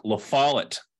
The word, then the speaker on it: La Follette: It's La Follette. Rhymes with wallet.